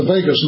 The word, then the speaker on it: vagus: So we have something called the vagus nerve, pronounced just like Las Vegas, but spelled V as in Victor, V-A-G-U-S, and it attaches at the 10th cranial nerve, just behind, and it goes down both sides.